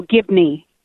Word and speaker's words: Gibney: No, Gibney, G-I-B-N-E-Y.